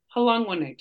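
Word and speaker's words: Hlongwane: Halongwane. – Yeah. – There you go. – Sounds like a fun greeting, doesn't it? Halongwane to you. I like it. Christian told me how to say it.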